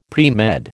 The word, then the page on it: premed: American English